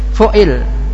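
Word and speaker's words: fu'il (فُعِل): Fu'il, bacanya fu'il.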